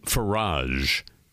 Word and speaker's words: Farage: There's a very popular, well, a notorious member of parliament, Nigel Farage, F-A-R-A-G-E, rhymes with Keldomage.